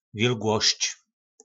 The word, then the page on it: vilglosc: po našimu